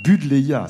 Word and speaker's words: Budleia: Boudléa, ça s'écrit B-U-D-L-E-I-A.